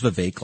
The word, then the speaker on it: Vivek: And, he told me it was Vivek like cake.